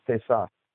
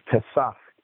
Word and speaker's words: Pesach: The Hebrew word is Pesach. – Thank you. Yeah, P-A-S-K-H-A in pronunciation. – Well, that's Latin. That's Pascha, but it's Pesach is Hebrew. Pascha is Latin.